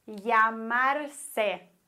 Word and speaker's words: llamarse: Regel Nummer 1, Doppel-L, genauso wie auch Y, wird wie ein deutsches J ausgesprochen. Doppel-L. Beispiele dafür wären llamarse, heißen, oder Mallorca, Mallorca, oder llegar, ankommen.